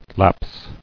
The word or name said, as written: Lapsing